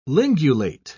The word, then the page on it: lingulate: British English